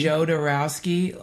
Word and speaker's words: Jodorowsky: Joe Dorowski.